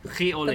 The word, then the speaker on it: riz au lait: Am I saying it right? Riz au Lait. – Oh, that's French, right? Okay, I did not get that the first time. – Oh my God, did I say that wrong? – Are you saying, milk, the milk one? Is it Riz au Lait, L-A-I-T, that one? – Riz-au-lait, yeah.